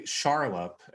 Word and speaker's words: Charlop: And my name is Dr. Gregory Charlop, and it's a funny one. It's spelled C-H-A-R-L-O-P.